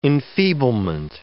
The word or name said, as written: enfeeblement